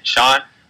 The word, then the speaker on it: Sean: My Facebook and Instagram, the same exact thing is Sean Freeman. That is spelled S-E-A-N, Sean. Last name Freeman, F-R-E-E-M-A-N.